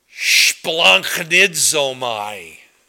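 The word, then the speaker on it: splanchnizomai: It's pronounced splanchnidzomai. Can you try it with me? Splanchnidzomai. There's a little bit of a ch in there. So splanch, it means your guts are churning.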